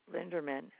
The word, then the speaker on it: Linderman: J-E-R-I. – All right. Last name, please. – Linderman, L-I-N-D-E-R-M-A-N. – That shipping address? – 9189 Somerset. It's spelled S-O-M-E-R-S-E-T.